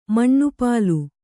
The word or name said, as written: ಮಣ್ಣುಪಾಲು